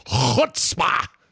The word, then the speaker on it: chutzpah: or say chutzpah. – You've pronounced that correctly. Most people can't do the part, but you've done it properly.